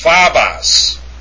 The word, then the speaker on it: phobos: What's phobos? It's phobos. Okay? I mean, get it. Look at it. It's not phobos. It's phobos. It's not phobos. It's not phobos. It's phobos. That's how you pronounce that form.